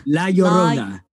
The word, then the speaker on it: La Llorona: How am I pronouncing it? – La Llorona. – La Llorona. – Yes.